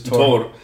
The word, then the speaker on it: Thor: and it's quite interesting because one of the one of the old pronunciations of Thor. – Yeah, is, Tor.